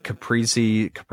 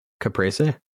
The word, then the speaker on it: Caprese: Then we walk into the actual like dinner part, and it was like caprese, whatever it is, caprese salad, and then Caesar salad, and then filet, and then salmon.